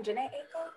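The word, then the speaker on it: Jhené Aiko: Janae Aiko.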